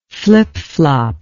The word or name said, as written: flipflop